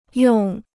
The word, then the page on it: 用: yòng